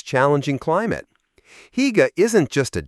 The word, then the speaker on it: Hygge: This is the world of Hygge, pronounced Hygge, Denmark's gift to our overworked, overstimulated society.